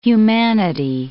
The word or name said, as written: humanity